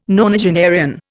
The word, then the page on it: nonagenarian: Indian